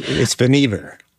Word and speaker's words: Vannevar: and it's Vannevar Bush or Vannevar Bush. You can tell us how to pronounce it here. – It's Vannevar. – It's Vannevar. – But everybody called him Van. I guess we can't. – That made it a lot easier. I was putting the accent on the wrong syllable there.